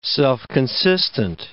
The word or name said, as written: self-consistent